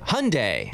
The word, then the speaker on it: Hyundai: Car brand, Hyundai, or Hyundai, however you say it. This is how you say it. Hyundai.